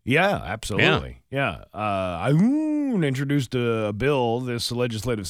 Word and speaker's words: Aune: It just doesn't make sense in 2024, said Representative Ashley Dune from Missouri. Uh, Aoun, I said, June, what's her name? Aune. A-U-N-E. – How would you pronounce that? A-U-N-E. – Aune. Werewolves of London.